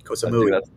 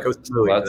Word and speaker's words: Koh Samui: I think I'm going to butcher this name, but Four Seasons Resort Koh Samui. – Koh Samui. – Samui? Yep. – Koh Samui.